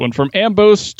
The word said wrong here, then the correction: Amiibos: to Skylanders. – Amiibos. – Say it one more time. – Amiibos. – Amiibos. From Amiibos to Skylanders, game companies have led the toys to life charge to translate real world objects into digital worlds.